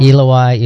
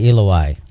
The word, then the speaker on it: Eloi: At the ninth hour, Jesus cried out with a loud voice, Eloi, how do you say that? – Eloi, Eloi, lama sabachthani.